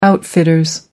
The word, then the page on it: outfitters: American English